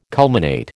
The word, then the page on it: culminate: American English